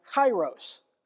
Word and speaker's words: kairos: This is pronounced kairos, from the Greek meaning full time.